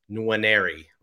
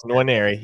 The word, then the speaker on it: Nwaneri: as a five star contender is Williams Nwaneri. right? A, that's perfect. That's the way to say it. That's the there way you go. Nwaneri.